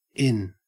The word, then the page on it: in: Australian English; stressed